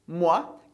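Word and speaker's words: mois: E como que você leria essa palavra com S do final? Exatamente da mesma maneira, a gente ainda diz moi. O S a gente não pronuncia e moi com S significa mês.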